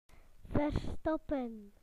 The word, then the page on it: to hide: British English